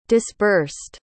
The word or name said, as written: disbursed